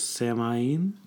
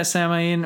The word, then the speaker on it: Samhain: Particularly the Gaelic festival of, I'm probably going to butcher this, Samhain? – Yeah, Samhain or Samhain. – Samhain.